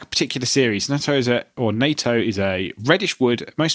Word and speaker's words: Nato: Pronounced NATO, and that's the NATO.